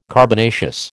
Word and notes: carbonaceous: American English

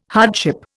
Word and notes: hardship: Indian

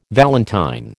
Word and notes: valentine: American English

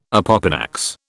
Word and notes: opopanax: British English

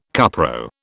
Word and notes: cupro: British English

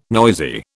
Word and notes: noisy: British English

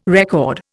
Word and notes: record: Indian

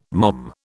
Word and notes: mom: British English

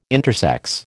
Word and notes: intersex: American English

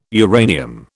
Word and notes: uranium: British English

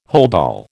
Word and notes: holdall: American English

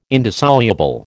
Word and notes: indissoluble: American English

